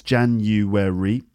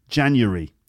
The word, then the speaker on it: January: But in my experience, in just normal conversation, people tend to say January. Three syllables. January.